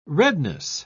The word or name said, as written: redness